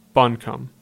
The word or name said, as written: Buncombe